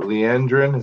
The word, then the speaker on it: Leandrin: When, was it Leandrin? Is that how we pronounce in her name? – Mm-hmm. Sounds good.